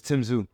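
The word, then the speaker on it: Tszyu: He's Australian. It's Tim Zoo.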